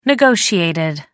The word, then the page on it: negotiated: American English